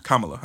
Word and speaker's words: Kamala: Kamala.